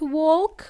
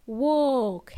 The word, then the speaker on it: walk: Remember, the word walk has a silent L. It's not walk. We don't say walk. It's walk, has a long O sound. I don't want to hear L in there, okay? Swallow it.